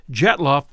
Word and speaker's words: Dyatlov: I checked Dyatlov before we started, and I've been pronouncing that correctly, as it would be pronounced in Russian.